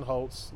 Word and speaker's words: Holts: And then for me, it's Wilson Holtz. Last name is spelled H-O-L-T-S.